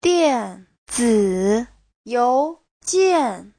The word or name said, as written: dian4zi3you2jian4